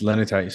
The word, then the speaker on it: Leanitai: Tell everyone where they can find Lenatai. Did I say it right? – Yeah, it's Lenitai.